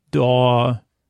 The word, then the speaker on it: Daw: Uh, she, she, uh, she's helped me more than once or a character very similar to me named uh, Daw, like Daw, like that rhymes with caw and uh, she, she uh, she's a friend you know she's a heroine uh, would be like an understatement.